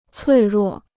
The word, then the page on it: 脆弱: cuì ruò